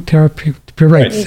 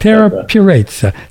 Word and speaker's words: Terra Purezza: Do I say it right now? Terra Purezza. – You got it. – Terra Purezza. See, I didn't get that.